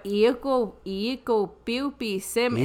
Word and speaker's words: Iikopiupisim: But Iikopiupisim is how you say. – That's the old Cree I use. – Here to get hurry up. – Iikopiupisim. That's that old Cree. Iikopiupisim.